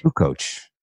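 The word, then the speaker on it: Kukoč: Is it Kukoc or Kukoc? – Kukoc. – Okay. – That's how I've heard it pronounced.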